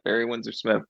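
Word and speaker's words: Barry Windsor-Smith: Windsor Smith, Barry Windsor Smith.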